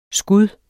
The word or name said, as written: skud